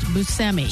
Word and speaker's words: Buscemi: In a recent interview, they pointed out the fact, and he did, that it's actually pronounced Buscemi. So he said it's hard to say, but it is pronounced Buscemi. – Steve Buscemi. – Steve Buscemi, yes. Now in Italy, in Italian, truthfully, it would be Buscemi.